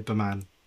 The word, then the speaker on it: BEMAN: How do you pronounce it? Is it Bee Man or Bee Men? Like how? – So it actually stands for something, and I say Baman, but I'm quite happy with people saying it's Be Man, because it is aimed at men's personal development and mental wellness.